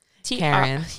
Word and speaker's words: Karen: It's at Karen Trisha, and that is spelled K-A-R-E-N. – Karen. – I'm Karen. It's fine. – Own it. – My middle name, T-R-I-C-I-A.